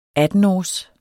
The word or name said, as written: attenårs